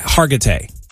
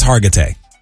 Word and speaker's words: Hargitay: Mariska, Hargitay. – Okay. – I believe is how you pronounce her name. I always just call her Olivia Benson. – Yeah. – I believe it's Hargitay.